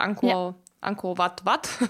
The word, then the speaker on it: Angkor Wat: Spricht man das richtig aus? Ist das Angkor, ja. Angkor Wat? Ja.